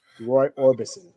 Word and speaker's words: Roy Orbison: Roy Orbison.